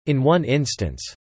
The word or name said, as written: in one instance